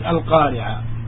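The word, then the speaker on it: القارعة: One of these names is Al-Qari'ah.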